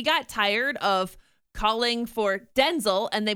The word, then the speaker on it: Denzel: No, it is not Denzel. It's Denzel. So the accent on the den. – Almost on the N.